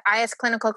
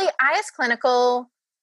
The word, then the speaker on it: iS Clinical: That's how you say it. I always say like is clinical because I think it sounds fun. Is clinical, IS, I don't know. – They say IS at Glowbar.